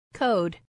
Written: code

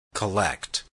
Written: collect

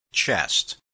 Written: chest

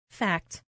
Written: fact

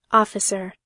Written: officer

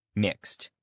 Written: mixed

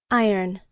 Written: iron

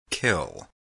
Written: kill

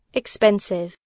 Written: expensive

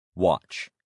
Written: watch

